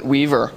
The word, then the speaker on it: W3VR: I pronounce it Weaver. It's my little cute pet name for it.